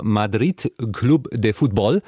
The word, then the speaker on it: Madrid Club de Fútbol: Und das Wappen, goldener Kreis, darin die Buchstaben MFC für Madrid Club de Fútbol.